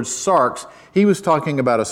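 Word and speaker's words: sarx (σάρξ): Whenever Paul talked about the flesh, most of the time when he talked about this theological, spiritual concept called the flesh, he used a Greek word pronounced this way, sarx.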